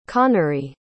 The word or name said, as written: conary